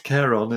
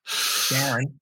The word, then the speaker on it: Charon: And everything that Rusty says is correct, that Pluto and Charon, as it's normally pronounced. – Charon. – We had a discussion before the show, didn't we, about how you should pronounce this? And I said, don't call it Charon. Charon. Anyway, I've heard NASA people call it Charon, so that's all right too.